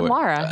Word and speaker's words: Lara: no, now you said Lara.